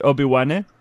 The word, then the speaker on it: Obi-Wan: Is it right that I pronounce it Obi-Wan? – Yes, perfect.